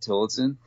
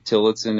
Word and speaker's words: Tillotson: So just M-A-T-T, Tillotson is T-I-L-L-O-T-S-O-N, and it'll come right up. – Okay. You don't pronounce the S in your last name. – No, it's kind of an I sound, so yeah.